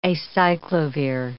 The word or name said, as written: acyclovir